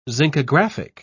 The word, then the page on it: zincographic: British English